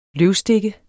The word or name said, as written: løvstikke